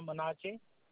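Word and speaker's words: Monaji: My name is Naga Manachi.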